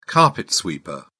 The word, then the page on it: carpet sweeper: British English